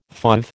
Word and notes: fauve: American English

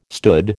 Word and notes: stood: American English

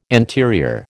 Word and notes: anterior: American English

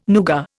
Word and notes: nougat: Indian

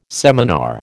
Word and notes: seminar: American English

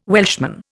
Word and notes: Welshman: Indian

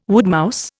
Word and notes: woodmouse: Indian

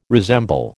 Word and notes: resemble: American English

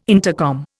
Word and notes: intercom: Indian

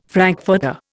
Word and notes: frankfurter: Indian

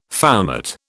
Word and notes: foumart: British English

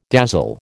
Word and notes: dazzle: American English

